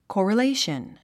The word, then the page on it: correlation: kɔ̀rəléiʃən; コラレイション